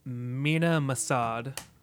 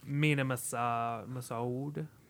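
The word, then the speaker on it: Mena Massoud: I think Aladdin's name is pronounced Mina Masad. – Okay. – Maybe. Don't quote me on that.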